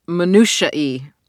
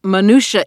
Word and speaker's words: minutiae: I realized I had trouble pronouncing minutiae. I have thought it was minutia for my entire life. I have said minutia many times out loud, and I still can't believe it's minutiae.